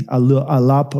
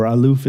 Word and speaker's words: aluf: It's L-L-L-U-P, alup or aluf is how you pronounce it.